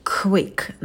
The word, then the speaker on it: quick: Быстрый – это quick. На звук «к» добавляем придыхание. Quick.